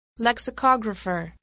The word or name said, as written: lexicographer